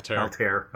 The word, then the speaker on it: Altair: I'm not sure how you pronounce it in English. And of course, it's an Arabic name. – Altair.